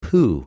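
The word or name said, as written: προς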